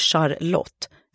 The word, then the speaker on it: Charlotte: Shirins kompis från studietiden, Charlotte, uttalat Charlotte, skulle gifta sig med en svinrik kille som gick under smeknamnet Bobbe.